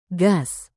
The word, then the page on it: gas: Indonesian